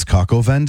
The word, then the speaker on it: coq au vin: How would you pronounce C-O-Q in French? – Like coq au vin? – What did you just say? – Coq au vin? – That's coq au vin day.